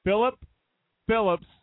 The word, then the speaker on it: Philip Phillips: Philip Phillips.